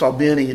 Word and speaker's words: Albany: which I think is properly pronounced Albany in Georgia.